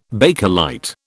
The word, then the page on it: Bakelite: British English